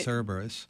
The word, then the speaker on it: Cerberus: And you're now in Dr. Cerberus. Am I pronouncing that correctly? – Yeah, that's right. – Cerberus.